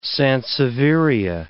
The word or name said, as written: sansevieria